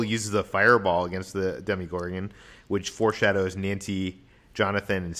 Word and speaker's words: Demogorgon: How do you even pronounce that? Demigod? – I think it's Demogorgon.